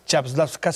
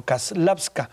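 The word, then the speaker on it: Čáslavská: Me llamó mucho la atención que hizo énfasis en que no se pronunciaba Chapslavska, sino Kaslavska,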